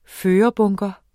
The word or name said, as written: førerbunker